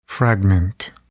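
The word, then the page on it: fragment: noun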